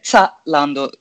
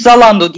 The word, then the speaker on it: Zalando: Hatta Almanlar böyle Zalando diyorlar ama ona bizim e, dilimiz çok yatkın değil maalesef. Düz Zalando deyip ge- geçebiliriz.